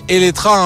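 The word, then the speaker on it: Eletre: Et oui, je dis Eletra, hein, même si ce nom de modèle il se termine par un E, car en fait c'est la prononciation en hongrois, et d'ailleurs ça veut dire qui vient à la vie tout un programme.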